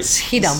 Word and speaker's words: Schiedam: a little bit about I never pronounce it right. Schiedam, is that right? – Schiedam.